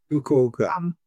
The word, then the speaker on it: Fukuoka: Fukuoka? – Fukuoka.